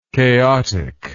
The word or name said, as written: chaoticism